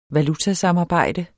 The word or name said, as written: valutasamarbejde